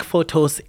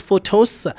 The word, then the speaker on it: Photos: On Facebook, it's Eric Photos. Eric, I'm going to spell it E-R-I-C. E-R-I-C. That's the type of my name. You spell it like that. E-R-I-C. And for my surname is Photos. It's P-H-O-T-O. P-H-O-T-O. And then you add an S, P-H-O-T-O-S, Eric Photos on Facebook.